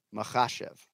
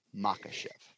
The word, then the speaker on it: Makhachev: So I did that for everybody this weekend because KH. Anytime you see KH, it's a ch. – Makhachev. – Right? – Not Makhachev.